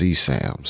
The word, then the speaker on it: CSAM: And that's the one I want to talk about first is the CSAM. Wait, is that how you say it? CSAM? – I think so. – Child sexual abuse material. Yes. CSAM, C-S-A-M.